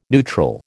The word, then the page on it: neutral: American English